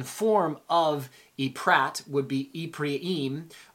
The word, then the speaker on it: Ephraim: Uh, Ephraim is how you would pronounce it in the Hebrew. Ephraim. Um, most people, uh, English, call it Ephraim, call him Ephraim